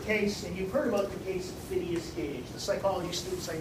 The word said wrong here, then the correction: Phineas: If you've not heard about Phineas Gage, it's spelled P-H-I-N-E-A-U-S. Phineas.